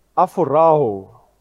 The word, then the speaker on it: aphorao: This word looking is the Greek word, and I want to pronounce this as accurate as I can. It is pronounced aphorao.